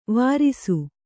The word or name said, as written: ವರಿಸು